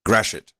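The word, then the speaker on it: Gratiot: There's a pronunciation for this. Graciot. I'm not sure. – I know it's Graciot. – Graciot.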